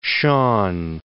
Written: Shawn

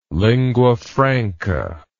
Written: lingua franca

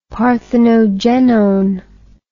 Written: parthenogenone

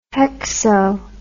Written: hexo

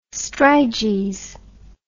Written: striges

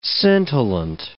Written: scintillant

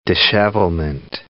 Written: dishevelment

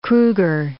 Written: Kruger

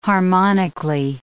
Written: harmonically

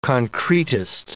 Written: concretist

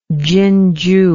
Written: chinju

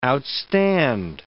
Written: outstand

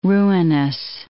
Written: ruinous

